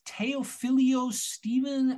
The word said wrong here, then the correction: Teofilo Stevenson: Teofilo Stevenson.